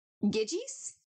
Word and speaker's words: Gyges: There was Briaros, Gyges. It's pronounced G-Y-G-E-S.